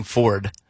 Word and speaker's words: forward: And then forward is the other one that's always gotten me. I can't say moving. It's moving forward. – Oh. – You know, like you're saying F-O-R-D. – Like America's pickup. Forward. I can't do it. – Two syllables.